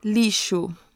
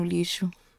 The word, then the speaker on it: lixo: Lixo. – Lixo. And that's spelled with an X, by the way. – Yeah, lixo. – You never know how X is going to be pronounced.